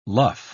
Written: luff

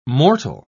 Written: mortal

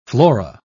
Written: flora

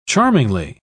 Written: charmingly

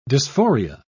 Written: dysphoria

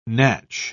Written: natch

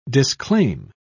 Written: disclaim

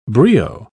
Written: brio

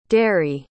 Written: deri

